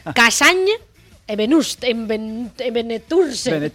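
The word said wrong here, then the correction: Benetússer: es Benetuser.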